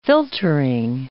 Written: filtering

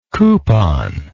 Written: couponless

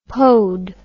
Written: pode

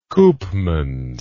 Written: Koopmans

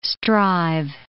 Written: strive

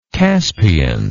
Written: caspian

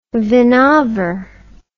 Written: Vinaver

